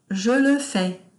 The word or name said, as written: je le fais